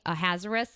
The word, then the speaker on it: Ahasuerus: so it, the kind of anglicized way is King Ahasuerus, which is probably what we'll be using.